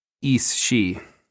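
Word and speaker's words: Aos sí: A-O-S, new word, S-I, and there's like a accent over the I. – It's the Irish pronunciation is Ishi.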